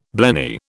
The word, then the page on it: blenny: British English